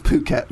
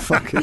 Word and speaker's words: Phuket: Yeah, but if you say to someone three times, it's pronounced Phuket, not fuck it. It's pronounced Phuket, not fuck it.